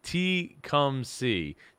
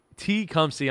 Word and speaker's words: Tecumseh: So T come C. That's how you say it. Okay. T come C. Tecumseh, Ontario, Canada. I did it.